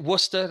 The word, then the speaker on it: Worcester: You got to say it, Worcester. That's how you say it.